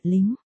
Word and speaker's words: Linh: Ninh là Ninh, tên của Diệp Ninh, phát âm là, nính, còn Linh nghĩa là số không kia thì là Linh, phát âm là, lính. Phát âm khác nhau mỗi L với N thôi mặt cười, Đại Mỹ Ninh nghĩa là Ninh Đại Mỹ Nhân.